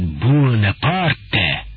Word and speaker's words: Буонапарт: Вы зовете его не иначе, как Буонапарт, с ударением на «у», словно роялисты. Надо сказать, что мой дед в этом отношении вас превзошел. Он произносит «Буонапарте». Я считал вас людьми молодыми.